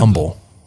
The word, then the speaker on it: Humble: Humble, like without the H, because where Dakota Allen was from.